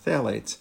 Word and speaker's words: phthalates: So, for example, one type of chemicals are called phthalates, which is spelled with a pH, oddly enough, but it's pronounced phthalates.